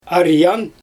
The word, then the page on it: arjang: Xyzyl